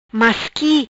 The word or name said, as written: Masqui